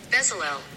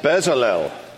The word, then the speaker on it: Bezalel: So, Bezalel. – That's the official pronunciation. Bezalel.